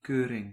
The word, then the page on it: inspection: /ɪnˈspɛkʃən/